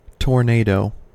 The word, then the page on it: tornado: American English